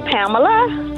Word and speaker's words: Pamela: My name is Pamela.